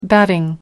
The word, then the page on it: batting: American English